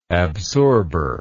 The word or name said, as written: absorber